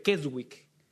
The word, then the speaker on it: Keswick: Un grupo que se denomina eh, los que creen en la teología de, se pronuncia, se escribe Keswick. Keswick, suena como a queso.